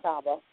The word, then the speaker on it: Catawba: Is that how I pronounce it? Catawba? – Catawba.